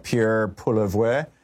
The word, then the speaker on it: Poilievre: Pierre Poilievre, or I guess French Canadian, you pronounce the R at the end, French, you don't.